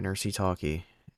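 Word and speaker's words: Nercy Talkie: Nursey Talkie, NERCYTALKIE. – The fuck? – Yeah, Nursey Talkie. It's a. That's how you pronounce it.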